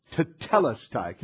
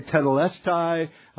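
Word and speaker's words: tetelestai (τετέλεσται): Now, you might have heard that pronounced differently. And some say tetelestai, et cetera, pronunciations. But for the purpose of this morning, where we're going, tetelestai is what I'm going to use.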